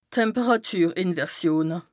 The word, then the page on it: d' Temperàtürinversione: Bas Rhin